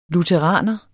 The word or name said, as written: lutheraner